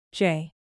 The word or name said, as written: jay